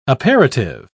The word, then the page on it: aperitive: British English